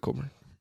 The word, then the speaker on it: Kofi Cockburn: Col- Colburn. He he pronounces it Colburn. – No, that, Kofi Colburn. Cockburn. – I mean that that's how I pronounce it because that's how it's spelled.